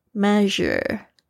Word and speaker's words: measure: 我们特别要注意的是，这个 SU 的发音是 R 的发音，不是 SH。 measure measure 它跟这个 television 它的 SI 的发音是一样的，是一个很特别的，很像 Z，但是必须要嘟嘴面变 Z 这个发音。